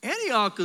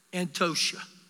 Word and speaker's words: Antioch: Now, Antioch is one of those words that when I went to Jerusalem, I was corrected. It's really not Antioch. You know how it's pronounced? Antosha.